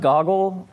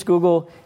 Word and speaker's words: Google: So before that, he sold a company to a small company that you might not have heard of I think it's pronounced Goggle. I'm not sure. Um, uh, maybe it's Google.